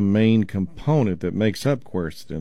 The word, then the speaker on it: quercetin: It's spelled Q-U-E-R-C-E-T-I-N, quercetin.